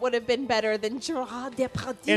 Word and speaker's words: Gérard Depardieu: Gérard Depardieu.